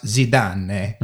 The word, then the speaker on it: Zidane: And I believe it's pronounced Zidane.